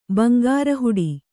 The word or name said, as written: ಬಂಗಾರಹುಡಿ